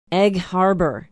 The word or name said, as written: egg harbor